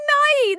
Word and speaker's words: Nighy: Bill Nighy!